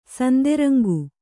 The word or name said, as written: ಸಿಂಧುರಂಗ